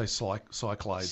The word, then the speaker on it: Cyclades: All right, the interweb is a bit excited about Matago's upcoming Innes board game, the Celtic-themed successor to Cyclades. – Yeah. – That's how I pronounce it anyway. Some people say Cyclades.